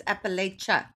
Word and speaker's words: Appalachia: I used to say that, and then David Joy corrected me, and he was like, it's Appalachia. And I always say Appalachia.